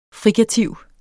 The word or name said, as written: frikativ